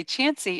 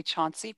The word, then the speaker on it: Chauncey: And you were talking about Chansey, and I just want to tell people, you have to know it's spelled Chauncey. The reason we know to say Chansey is because if you say Chauncey, people know you're not from around here. – Yes. – So you will want to say Chansey, but it is spelled Chauncey. Yes. So Chauncey is the spelling, Chansey is the pronunciation.